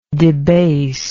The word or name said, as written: debasingly